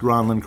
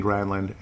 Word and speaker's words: Granlund: It is pronounced Granlund correctly, but a lot of us got used to saying Granlund, and of course, he was okay with it, basically. He said, go ahead and call me Granlund.